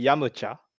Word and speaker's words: Yamcha: Wait, that's a pronunciation in Japanese, like the proper one? – Well, the proper one would be Yamcha.